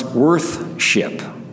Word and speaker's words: worship: Now, our word worship used to be pronounced worth-ship.